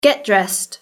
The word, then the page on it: get dressed: British English